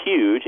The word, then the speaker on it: huge: I think a really great one, speaking as a linguist, is the word huge, which is huge, H-U-G-E, with a Y sound at the beginning instead of the aspirated H.